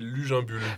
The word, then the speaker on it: Luginbuhl: Tristan Lujambul, c'est comme ça qu'on dit ? – Pas mal, c'est Lujambul.